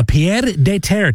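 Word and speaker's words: pied-à-terre: A pied de terre tax.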